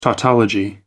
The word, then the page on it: tautology: American English